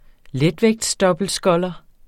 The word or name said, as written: letvægtsdobbeltsculler